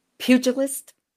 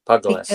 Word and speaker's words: pugilist: And from that, we're working towards a pugilist. Is that how you say it? Who enters the ring? – Pugilist. Because pugilist. Okay, we pronounce the G.